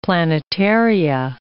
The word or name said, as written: planetaria